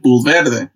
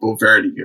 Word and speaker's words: Bulverde: He pronounced the bull part like how you would say it in English, like bull. He said verde, you know, like the color. It's like bull verde.